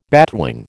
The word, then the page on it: batwing: American English